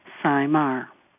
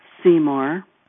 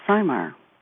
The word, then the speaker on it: Simar: Simar is pronounced Sai as an I and Mar as in car. Simar. I've been called many things throughout my married life, Simmer, Somor, Seymour, but the correct pronunciation is Simar.